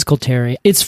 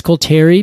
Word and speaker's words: coulteri: Coulter pine, Pinus coulteri. It's funny when you said that, Pinus coulteri, because I always pronounced it Pinus coulterii. And that's not it. There's only one eye there.